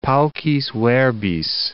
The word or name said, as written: paucis verbis